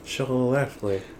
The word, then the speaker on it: Schlafly: Schlafly. Schlafly. – You had a little bit of an extra, like, syllable there. – The rum. – Schlafly.